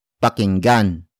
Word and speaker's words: pakinggan: If the focus of the sentence is the person or object that you are supposed to listen to, makinig becomes pakinggan, pakinggan, pakinggan, which is spelled as P-A-K-I-N-G-G-A-N.